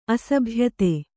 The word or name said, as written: ಅಸಭ್ಯತೆ